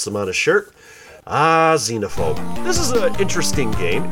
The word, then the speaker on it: Xenophobe: By the way, Vert Vic, it is pronounced Xenophobe. – Xenophobe. – Don't believe me? Go back to episode 24 and listen to what Brian Cullen said. He said Xenophobe.